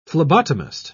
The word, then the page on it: phlebotomist: British English